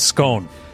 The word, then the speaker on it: Skåne: I'm not sure how to pronounce the A. It has one of those. – Skåne. – Skåne County, Sweden.